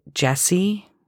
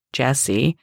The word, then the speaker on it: Jesi: they announced it Jesi, and then this sort of light bulb went off in my head. I was like, oh, I've been pronouncing this wrong the entire time. So now I try and do it right. I try and call it Jesi.